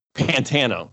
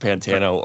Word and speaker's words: Pantano: Pantano. Okay. Well, we record everything with Pantano, or just imagine that we did.